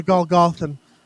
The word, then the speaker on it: Golgothan: The Golgothan? Is that how you pronounce that? – The Golgothan.